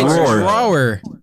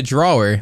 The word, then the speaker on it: drawer: It's drawer. Dude, why there's a W? – You're pissing a lot of people off. You understand it's the English language. You don't have to pronounce every fucking letter in the word.